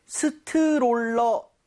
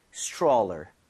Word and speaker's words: stroller: 자, 근데 발음할 때 스트롤러 아, 이건 좀 아닌 것 같아요. – 아쉽네요. – 이 모양을 좀 많이 모아서 R 사운드 제대로 돼서 약간 치읓 비슷하죠? Stroller처럼 네. – 살짝 변하게 여러분들 이 모양 모으시고 혀 많이 당겨주세요. 자, 발음 갈게요. Stroller.